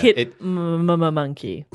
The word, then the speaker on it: Hitmonkey: And the character we're talking about is a Marvel character called Hitmonkey. Hitmonkey. Is that how they pronounce it? – That's how you pronounce it. Yeah. – Hitmonkey.